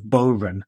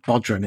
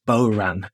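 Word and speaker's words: bodhrán: I feel like after in the last podcast, my mispronunciation of Bohran, which apparently doesn't have a D, it's not Bodran, it's Bohran.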